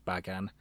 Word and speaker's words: Bagan: He was going to, after they cancelled Resurrection of Godzilla, and therefore Bagan's, I looked up how to pronounce it, I'm pretty sure it's Bagan. It doesn't sound right.